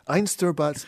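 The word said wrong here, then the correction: Einstürzende Neubauten: I think it's Einsturzende Neubauten. Einsturzende Neubauten. Yeah.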